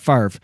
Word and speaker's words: Favre: He has like a French looking name, but he pronounces it Favre. It's not like, you know, well, I guess if you wanted to like, you know, North Americanize it, it could be Favre, but whatever.